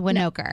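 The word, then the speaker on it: Winokur: I was like, I don't want to mess it up. It's Winoker.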